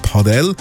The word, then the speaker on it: Padel: Aber eben, ist aktuell mega im Hype, das Paddel, oder richtig ausgesprochen sagt man es eben Padel.